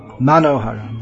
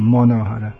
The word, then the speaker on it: Manohara: Manohara, Bengali pronunciation, Monohara. Monohara.